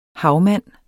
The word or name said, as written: havmand